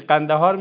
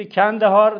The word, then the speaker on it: قندهار: قندهار میری؟ اگه میگفت قندهار، خب میگفت این شیعه است، چون قاف میتونه تلفظ کنه. بعد میگفتی کندهار تا نفهمن مجار.